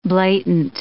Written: blatant

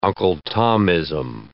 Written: Uncle Tomism

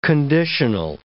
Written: conditional

